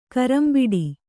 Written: ಕರಂಬಿಡಿ

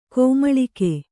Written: ಕುಮುಲಿಕೆ

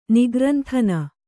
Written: ನಿಗ್ರಂಥನ

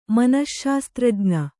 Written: ಮನಶ್ಶಾಸ್ತ್ರಜ್ಞೆ

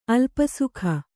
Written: ಅಲ್ಪಸುಖ

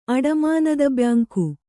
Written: ಅಡಮಾನದಬ್ಯಾಂಕು

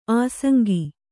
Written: ಆಸಂಗ

